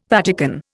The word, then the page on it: Vatican: Indian